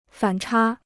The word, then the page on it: 反差: fǎn chā